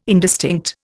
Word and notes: indistinct: Indian

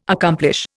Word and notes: accomplish: Indian

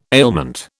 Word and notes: ailment: British English

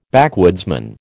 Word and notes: backwoodsman: American English

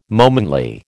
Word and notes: momently: American English

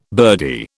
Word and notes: birdie: British English

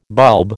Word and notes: bulb: American English

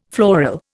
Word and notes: floral: Indian

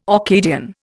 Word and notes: Orcadian: Indian

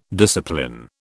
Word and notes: discipline: British English

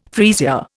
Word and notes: freesia: Indian

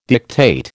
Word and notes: dictate: American English

